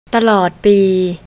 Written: talod

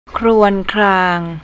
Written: krownklang

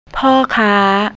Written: porkar

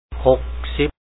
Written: 60

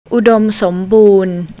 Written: udon

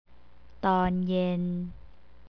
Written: tonyen

